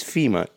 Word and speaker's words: Fima: My name is Fima, just like the organization, but spelled with an I.